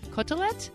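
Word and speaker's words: côtelette: Okay, so the word katsu comes from the English word cutlet, which derives from the French word, I don't know how to pronounce this, is cotelette? C-O-T-E-L-E-T-T-E?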